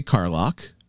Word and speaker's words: Karlach: Carlock. K-A-R-L-A-C-H. That'd be Carlock.